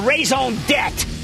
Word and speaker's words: raison d'etre: You know, sometimes you need what I call raison d'etre. I learned that in French class. You don't pronounce the R-E, debt.